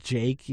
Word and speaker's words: JVKE: It took me a surprising amount of time to realize that it was just Jake, even though it was spelled J-V-K-E. That, like, oh, that's how you're meant to say it. I was like, I kept trying to pronounce the V, and then someone told, there's no V.